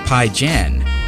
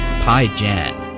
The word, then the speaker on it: PYJÆN: I learned the pronunciation of the artist with the name spelled P-Y-J-A-E-N. I was actually really close. It's Pyjan. Pyjan.